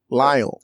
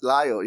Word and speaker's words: Lyle: I called it Little, but it's pronounced Lido or Lido. – Lyle. – Lyle, yes. – Yeah. – I don't know why I say Little, but this.